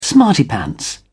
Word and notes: smarty pants: British English

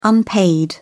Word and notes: unpaid: British English

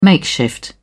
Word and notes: makeshift: British English

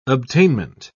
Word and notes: obtainment: British English

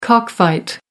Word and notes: cockfight: American English

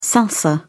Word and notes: salsa: British English

